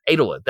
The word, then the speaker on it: Edelwood: Edelwood, that's what it was. Sorry, it's spelled E-D-E-L, and I had lost track of how it was said on the show.